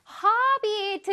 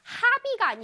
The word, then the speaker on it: hobby: 허비 드디어 나왔습니다. 허비, 허비, 허비. 이건 발음이 그렇게 어렵지 않아? 해볼게요. 하비가 아니라 얘들아, 하비가 아니라 턱을 아래로 내려야 돼요. 허비, 허비.